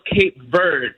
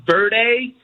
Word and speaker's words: Cape Verde: to say also, I don't want to be the pronunciation police, but I did have to say that it is pronounced Cape Verde. It's not Verde, because in Portuguese, you would say Cabo Verde. But in English, it's just Cape Verde.